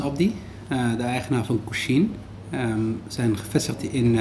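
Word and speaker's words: Kushiin: Nou, vandaag ben ik bij een koffietentje eh, genaamd Kushin. Zeg ik het goed? – Ja, je zegt het helemaal goed.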